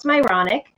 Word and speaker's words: Myrony: So my last name is literally pronounced Myronic. So everyone has these Myronic experiences, just not the way I spell it. That's Myrony.